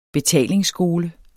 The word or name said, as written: betalingsskole